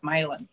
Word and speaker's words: Mylan: It's an Irishman who came and then changed his name because he wanted people to think he was Irish, so he sounded French, but he pronounced it like an Irishman, so it's Mylan.